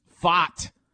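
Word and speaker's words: Pfaadt: Game three tonight, you've got Scherzer for the Rangers, Fott, which is a fun name to say. The P is silent. Fott for the Diamondbacks tonight, 7 o'clock.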